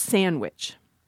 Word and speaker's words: sandwich: For many speakers, what happens is that we will delete the D. So then you've got sandwich. Not impossible to say at all, but I want to pause here to think about the pronunciation of W and the pronunciation of N because it helps explain why some of us say sandwich. If you say the sound W, wuh, you'll notice that your lips come together.